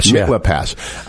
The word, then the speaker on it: Mitla Pass: And so there's at one point in the book, if you don't understand 56, you can't understand 67, the Mikvah Pass. Did I say that right? – Mitla Pass.